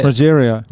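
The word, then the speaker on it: Rogério: It's not Rodrigo. How do you say it? – Rogerio. – Rogerio. – Oh, is that it? Oh, yeah. – She's Marcos Rogerio de Lima. – Marcos Rogerio de Lima.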